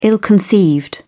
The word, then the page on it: ill-conceived: British English